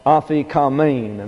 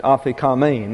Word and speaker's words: aphikomen: So we have A-P-H-I-K-O-M-E-N. Now this is an eta, and it's pronounced like a long English A. And so we have aphikamein, and the last syllable is the one that is accented, aphikamein.